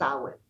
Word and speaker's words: Samhain: I will tell you how the term Halloween, but you'll hear witches say Happy Samhain, and it's spelled Samhain. So it looks like it's Samhain, but it's pronounced Samhain.